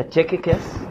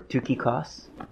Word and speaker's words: Tychicus: really close, yeah, Tychicus or Tukikos. And if you're, that's how you pronounce it in Greek, but the Tychicus also more than likely penned Colossians.